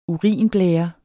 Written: urinblære

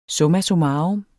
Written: Summa

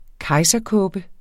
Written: kejserkåbe

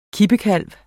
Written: kippekalv

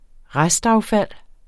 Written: restaffald

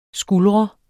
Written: skuldre

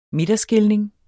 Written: midterskilning

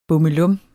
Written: bummelum

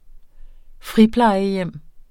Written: friplejehjem

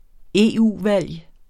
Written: EU-valg

